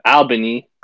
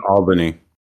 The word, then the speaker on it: Albany: Irvine is bigger than Albany. It's bigger than Albany. You say it wrong.